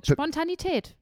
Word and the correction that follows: Spontaneität: Nein, das Zweite ist Spontaneität. – Spontaneität. – Ja. Und zwar auch so mit der Betonung, Spontaneität.